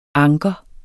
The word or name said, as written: anker